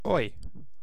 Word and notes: oi: British English; /ɔɪ/

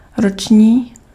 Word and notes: annuel: France; [a.nɥɛl]

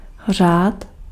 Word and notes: řád: [r̝aːt]

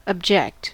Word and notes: esine: ˈe̞sine̞ˣ

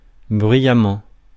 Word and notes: bruyamment: France; [bʁɥi.ja.mɑ̃]

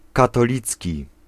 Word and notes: Catholic: [ˈkæθ.lɪk]; /ˈkæθ.ə.lɪk/

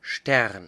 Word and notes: star: /ˈstɑɹ/; [stɑː]; [stɑː(ɹ)]; [stɑ˞]

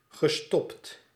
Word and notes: abbrechen: [ˌapʔ.'bʁɛː.çən]; [ˈʔapˌbʁɛçn̩]; [ˈʔapˌbʁɛçən]

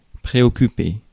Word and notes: préoccuper: France; [pʁe.ɔ.ky.pe]

